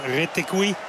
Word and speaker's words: Retegui: Matteo Retegui. En dat moet je op zijn Argentijns uitspreken, want daar is hij geboren.